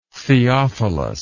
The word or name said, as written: theophilus